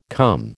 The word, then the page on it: come: American English